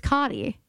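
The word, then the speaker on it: Kati: Like C-O-T-T-I-E. Yeah, but it's Kate. It's Connie. It's spelled like, no, her name's Cottie. It is Cottie, but it's K-A-T-I. It's spelled like Katie, but it's Cottie.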